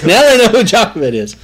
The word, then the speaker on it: Jochebed: What about Jochebed? Have you ever heard of Jochebed? Some pronounce it Jochebed. Jochebed.